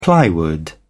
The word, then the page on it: plywood: British English